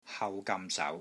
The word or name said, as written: HAU6 GAM6 SAU2